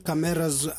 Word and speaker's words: Kameraz: And that's called Kameraz. I say it like that because it's with a K in the beginning.